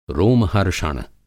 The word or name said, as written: रोम-हर्षण